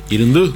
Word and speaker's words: Irundú: Irundú. Porque dicen que no se pronuncia como Irundú o Irondí. – Es Irundú. Claro, la U nuestra tiene, en esta pronunciación tiene un tono distinto.